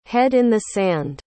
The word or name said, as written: head in the sand